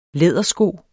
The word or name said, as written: lædersko